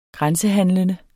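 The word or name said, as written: grænsehandlende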